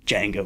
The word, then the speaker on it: Django: He's like, Django. He's like, can you spell it? He's like, the D is silent. He's like, I know it is.